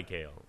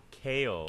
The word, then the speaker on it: Kael: I go by Kale, but I spell it Gale. – No, Kale. – Kale. – Phonetically like the vegetable. – Phonetically like the vegetable. – But spelled K-A-E-L.